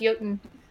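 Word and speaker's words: Jötunn: It's J-U-T-O-N-N, I think, but it's pronounced Jotun.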